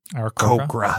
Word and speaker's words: Aarakocra: I'm really bad at saying Aarakocra.